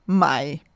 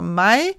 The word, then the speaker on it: Maille: som hedder MAI. M-A-I-L-L-E. To L'er på fransk udtalt som et J, så derfor MAI.